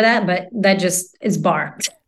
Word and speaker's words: barre: the barre. Is it barre? Is that how they say it? Or bar? – Bar, yes. Bar.